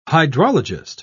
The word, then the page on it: hydrologist: British English